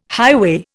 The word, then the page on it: highway: Indian